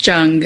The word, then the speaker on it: Jung: It's Jung. It's a hard J, but it's okay. Everyone says Young.